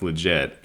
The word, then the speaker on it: LGIT: This was on the Not Really Pod the other day where they said that we should abbreviate this whole long-ass LGBT, QIA, whatever thing to LGIT and pronounce it legit because it's the only legitimate sexualities.